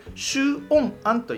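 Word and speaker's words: 酬恩庵: 正式名称をね、周恩庵と言います。 – 周恩庵。 – そう、周恩庵。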